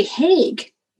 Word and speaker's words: Hauge: How do you say H-A-U-G-E? How do you say that? – Hague. – You say Hague.